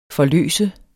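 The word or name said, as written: forløse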